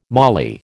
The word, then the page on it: moly: American English